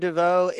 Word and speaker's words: Deneuve: It says that Catherine Deneuve is I can't pronounce her name.